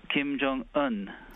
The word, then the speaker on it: Kim Jong-un: Kim Jong-un.